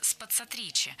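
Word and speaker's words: spazzatrici: «Ты знаешь, что значит слово «спацатриси»?» «Оно произносится «спацатричи», — поправляет она, проговаривая слоги. «Ударение на три. Это значит «чистильщики». Попробуй произнести его помедленнее».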